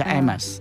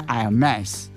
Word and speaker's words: Hermes: 法国人好像把重音摆在前面，叫 Hermes 爱马仕。嗯，那法国那个名牌哦，创办人的姓就真的跟他一样哦。对。那英文的发音是重音放在后面，叫 Hermes，就这样子。